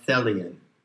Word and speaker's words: Thelian: And if you recognize and dwell in that which is intended, then you are a Thelian, rhymes with Hellion,